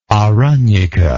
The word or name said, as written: Aranyaka